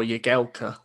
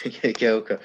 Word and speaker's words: Jagielka: I'm going to go Phil Jagielka, or Jagielka, as apparently it's pronounced. – Jagielka.